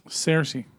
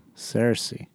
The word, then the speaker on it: Saoirse: Saoirse. – Saoirse.